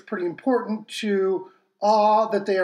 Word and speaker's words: Oux: Uh, then we have a band called Awe, and that is spelled O U X.